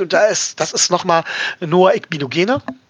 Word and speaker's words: Igbinoghene: Ich hatte die einzelnen Silben bei der Sonderfolge falsch ausgesprochen. Das heißt, Igbinoghene. – Ja, Noah Igbinoghene.